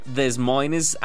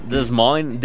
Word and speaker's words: Des Moines: Yo antes decía Des Moines, Iowa, pero es Des Moines, Des Moines.